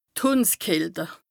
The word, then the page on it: d' Hùndskälte: Bas-Rhin; Strasbourg